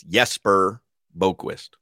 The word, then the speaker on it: Jesper Boqvist: It's Jesper Boqvist. So you almost got it. You only got the first and last name wrong.